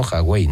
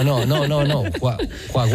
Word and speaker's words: Huawei: Se dice así, ¿no? Huawei, ¿no? – O Huawei. – ¿Eh? – O de chino Huawei. O de justita. – No. Huawei. – Como de sueco. – Igual. Huawei, ¿no?